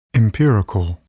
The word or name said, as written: empirical